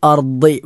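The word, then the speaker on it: അർദ് (أرض): വൽ അർദി, വൽ അർദി എന്ന അർദ്, ഭൂമി എന്നർത്ഥമുള്ള അർദ്, അത് ദാദാണ്.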